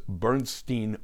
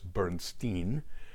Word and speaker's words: Bernstein: and Elmer Bernstein was known as Bernstein West because he was in Hollywood in Los Angeles. They pronounced their names differently as well. Elmer pronounced his last name as Bernstein, and Leonard his last name as Bernstein.